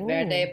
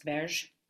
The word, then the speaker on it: Verde: It looks like Verde, but I believe in Portuguese it's pronounced Verge.